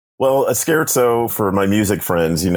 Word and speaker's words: scherzo: A scherzo. Mm-hmm. Am I pronouncing that correctly? – Perfectly correct.